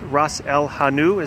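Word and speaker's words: ras el hanout: So a name that I find really interesting is the Moroccan lamb and fig with Ras El Hanou. Is that the correct pronunciation? – Yes, it is.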